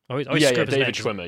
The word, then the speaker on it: David Schwimmer: David Schwimmer, yeah. – It looks weird. Anyway, but David Schwimmer gives a quote in here, and it's the funniest thing. David Schwimmer